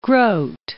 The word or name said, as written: Grote